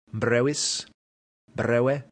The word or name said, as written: brevis